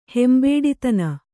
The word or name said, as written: ಹೆಂಬೇಡಿತನ